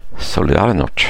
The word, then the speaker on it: Solidarność: Den klassiska fackförbundet, hur uttalar man det här? Solidarność. – Solidarność.